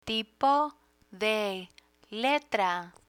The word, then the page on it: tipo de letra: spanish